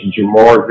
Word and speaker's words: Jemarr: And my name is Jamarr Vincent. It's spelled J-E-M-A-R-R Vincent, V-I-N-C-E-N-T.